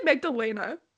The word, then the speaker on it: Magdalena: Okay, but I pronounce it Magdalena. Is that wrong? – No, I think you're pronouncing it the right way. I'm pronouncing it like the English way.